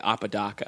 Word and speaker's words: Apodaca: I feel like I've changed the way I've said it to sort of like not embarrass people maybe, but like I don't know what like the correct like ethnic pronunciation is. Mm-hmm. But I know that like because I'm from Southern California, I say Apodaca.